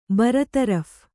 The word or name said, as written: ಬರತರಫ್